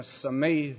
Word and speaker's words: Simi: And I'm sure that if you lived in the Simi Valley, and I'm told that's the correct way to pronounce that valley, that it would mean a disaster.